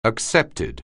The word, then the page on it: accepted: anglais